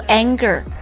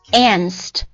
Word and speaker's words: angst: 它不是读 anger 也不是读 ants 它是读 anst A-N-G 加上一个 S-T anst